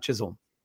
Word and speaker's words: Chisholm: The Moose put out a guide for their names, and I always thought, because it's spelled with an L, but it's Chisholm, not Chisholm.